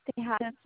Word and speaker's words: Tejada: My last name is Tejada Tango Echo Juliet Alpha Delta Alpha.